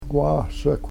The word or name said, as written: wahsekw